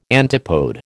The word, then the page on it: antipode: American English